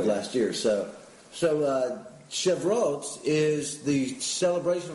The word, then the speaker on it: Shavuot: It's Shavuot. – Shavuot.